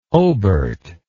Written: oberth

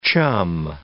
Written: chum

